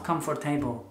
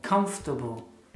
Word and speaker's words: comfortable: Comfortable, not comfortable. Comfortable. Three syllables.